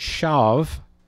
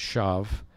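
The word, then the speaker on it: shav (שוא): But the Hebrew word shav, with a W, which is pronounced like a V, Sandra, S-H-A-W, pronounced shav.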